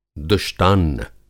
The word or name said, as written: दुष्टान्न